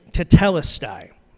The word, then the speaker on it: tetelestai: Pronounce it this way tetelestai. So let's practice together. Tetelestai. Okay, ready? One, two, three.